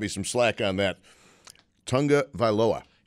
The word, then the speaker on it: Tagovailoa: It's Tua Tagovailoa. – Tagovailoa. Tagovailoa. I got to call my friend. – Even though there's no N in it, it's Tagovailoa.